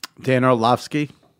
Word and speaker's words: Orlovsky: Dan Orlovsky.